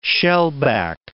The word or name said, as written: shellback